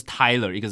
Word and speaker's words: Tyler: 但其实他们两个这个名字呢，这个名它的发音还是不一样，一个是 Tyler，一个是 Taylor，对，但是一个是 T A Y L O R，一个是 T Y L E R。 – 哎，这个 L E R 跟 L O R 一定很多人还是会拼错，我觉得啦。 – 其实有时候我知道他叫 Tyler Taylor，有时候你还是会。